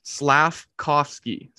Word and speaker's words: Slafkovsky: How do you say it? – This is This is why you got me here, boys. Slafkovsky.